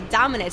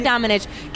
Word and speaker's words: Domenech: That's what, okay, Domenech. Sarah Domenech.